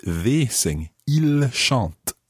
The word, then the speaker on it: ils: So we've got il in the plural, I L S, il. – Il. – But the S is silent unless it's followed by a vowel.